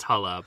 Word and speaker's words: Harry Hole: He plays a man named Harry Hole. – Right. – Which I do believe is pronounced Hulla, but...